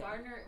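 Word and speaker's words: Gaertner: It's spelt a weird way, too. It's G A E R T N E R. So Belva Gardner was actually born Belva Eleonora Boosinger on September 14th, 1884.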